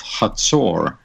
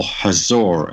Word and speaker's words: Hazor: Another historical evidence is there's tremendous archaeological digging in the city of Hazor, or Hazor, as some people may pronounce it.